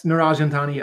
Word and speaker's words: Niraj Antani: Senator Niraj Antani. Am I pronouncing that correctly? I hope so. – Niraj Antani, yes.